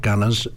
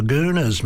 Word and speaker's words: Gooners: It's not Gunners; they call them you're the Gooners, mate.